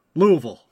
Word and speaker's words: Louisville: Remember, kids, it's pronounced Louisville.